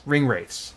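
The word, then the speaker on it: Ringwraiths: Ring Wraiths.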